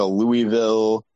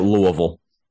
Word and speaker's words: Louisville: like a Louisville would make sense. So I really don't know. – But you got to say it, Louisville. – Yeah, Louisville. Yeah, I'm sorry. I said it wrong.